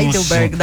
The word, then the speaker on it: Heidelberg: Pela Universidade de Heidelberg, acho que é assim a pronúncia. – Heidelberg, da Alemanha.